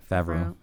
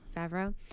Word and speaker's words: Favreau: I get that Kevin Smith and, and John, how do you pronounce it? Favreau. – Favreau.